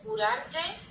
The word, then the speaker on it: curarte: Para comerte el alma. – To heal, pa curarte el alma.